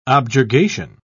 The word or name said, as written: objurgation